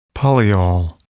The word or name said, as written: polyol